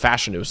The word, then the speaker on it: Fashanu: This isn't some like new revelation that we've had for Olu. Actually, Fashanu. Actually, he put out there his name is pronounced Fashanu.